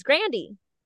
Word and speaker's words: Grande: Because her last name isn't even Grande, it's Grandy. – Grande, yeah. – Yeah, it's like she says it's pronounced like Brandy, but Grant with a G.